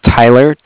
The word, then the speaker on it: Tyler: Okay, my name is Tyler, T-Y-L-E-R, and I'm calling with Cardiff. It's spelled C-A-R-D-I-F-F, as in Frank.